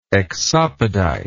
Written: exopodite

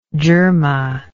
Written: djerma

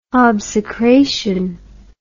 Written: obsecration

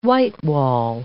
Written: whitewall

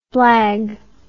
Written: blag

